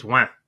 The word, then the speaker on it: ouin: It would be oui, right? Like everybody's heard the French say oui. Here it's ouin. Right? So like, yeah. Like if you, it almost sounds like a duck.